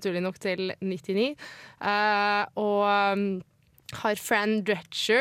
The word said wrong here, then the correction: Drescher: Jeg er litt usikker på hva jeg Drescher. – Drescher, Er det sånn man uttaler altså? – Jeg tror det er Drescher.